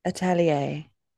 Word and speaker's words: Atelier: Like we could definitely see how things flowed in Witch Hat Atelier. That, did I say that right? – Atelier.